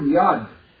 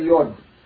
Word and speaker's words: Yad: Interestingly enough, I know very little about the Yiddish language, almost nothing, in terms of its grammar, but the word Yad, Yad is written with the commas, but it's always pronounced in Yiddish with a fatah. Ah, Yad, tamid, without exception. Never say Yod.